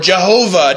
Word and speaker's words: Jehovah: If we were to pronounce this from the Hebrew, you get either Yahweh or Jehovah, depending on how you place the vowels in the Hebrew word.